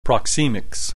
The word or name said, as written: proxemics